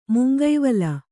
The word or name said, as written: ಮುಂಗಾವಲು